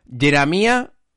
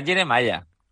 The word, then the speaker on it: Jeremiah: Jeremia con Jeremiah Springfield. – Escucha, pero Jeremia con H. ¿Sabes dónde? Al final de la palabra. – Ah, pero será Jeremiah. – Jeremiah. – Jeremiah, como Jeremiah Springfield.